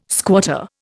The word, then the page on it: squatter: Indian